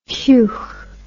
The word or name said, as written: heugh